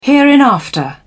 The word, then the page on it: hereinafter: British English